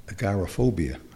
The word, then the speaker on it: agoraphobia: because Jefferson had agoraphobia, I think is how you pronounce it. – Yep.